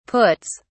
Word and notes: puts: British English

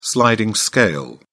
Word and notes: sliding scale: British English

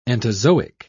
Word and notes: entozoic: British English

